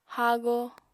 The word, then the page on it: haago: Apatani